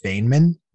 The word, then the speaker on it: Feynman: I believe it's pronounced Feynman.